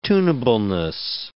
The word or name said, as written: tunableness